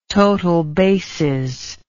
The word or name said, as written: total bases